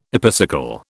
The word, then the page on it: epicycle: British English